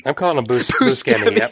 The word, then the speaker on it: Buscemi: Buscemi? – He gets introduced as Buscemi, but when the topic gets brought up, Buscemi admits that while that pronunciation is correct, so is Buscemi.